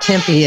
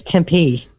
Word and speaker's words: Tempe: told that it is not Tempe, it's Tempe.